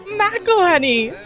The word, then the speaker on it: McElhenney: I learned how to pronounce Rob McElhenney.